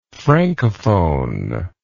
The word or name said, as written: francophonie